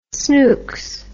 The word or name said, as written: snooks